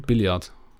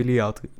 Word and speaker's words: Billard: Stopp, wie hast du das gerade ausgesprochen? Billard. Ja, weil du hast gerade irgendwie Billiard, Billard gesagt.